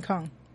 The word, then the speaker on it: Chong: If I say it Jin Chong, it sounds sort of racist. Right. – Yeah. – I'm sorry. Jin Chong. – I thought it was Jin Kong. – Jin Kong. You're right. But it's spelled C-H-O-N-G.